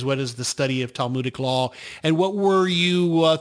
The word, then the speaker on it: Talmudic: You studied, and I'm probably going to pronounce this incorrectly, uh, Talmudic law. Is that did I pronounce that somewhat close? – That that is that is exactly yeah, Talmudic law.